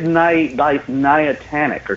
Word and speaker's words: Niantic: And the funny thing is that actually the company, I don't know how you pronounce it, Nindic? Whatever. – I think it's Niantic or something like Niantic. – That. – Niantic? I can see, yeah, that makes sense.